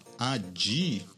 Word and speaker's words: Addyi: existe uma droga chamada ADI é meio a pronúncia é assim mas escreve A D D Y I que é uma pílulazinha rosa por incrível que pareça que é ah, um.